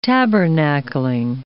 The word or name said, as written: tabernacling